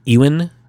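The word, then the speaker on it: Ewen: Then last but not least is a gentleman named Ewan Bremner. I believe is how you pronounce it. It's E-W-E-N. And it's Ewan.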